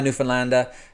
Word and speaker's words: Newfoundland: But if as a quick, I was taught by a Newfoundlander who definitely knew how to say it. The best way to say it is to rhyme it with understand Newfoundland. That's how it goes.